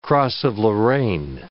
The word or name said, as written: cross of Lorraine